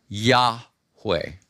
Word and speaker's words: Yahweh: we think it is likely pronounced Yahweh.